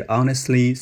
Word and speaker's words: honestly: 注意这里要点一下发音啊，我这里读的是 honestly speaking，那个 t 呢，honest t 被我给吃掉了。